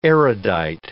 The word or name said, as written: erudite